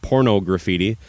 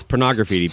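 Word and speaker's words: Porno Graffitti: Anyway, Extreme Porno Graffiti, or as almost everybody in the world mispronounced, Porno Graffiti. It's Porno Graffiti, people.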